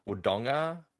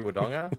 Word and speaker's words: Wodonga: Wodonga. With a hard G.